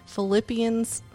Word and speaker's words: Philippians: Philippians 4:8.